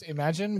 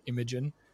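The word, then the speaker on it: Imagen: We got Imogen in May 2022. Or Imagine. Apparently it's pronounced Imagine, which makes a lot more sense because, anything you can imagine, but I've been calling it Imogen.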